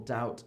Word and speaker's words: doubt: So subtle, doubt, and debt. We don't say the B in any of these.